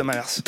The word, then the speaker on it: Mamers: Mais tu prononces le S, oui. – Ah, on prononce le S de Mamers.